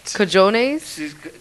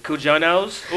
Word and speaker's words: cojones: How'd you say it? – Cajones? – Cujonos? – Ooh. – No. – Cujonos. It's not a Jonas brother.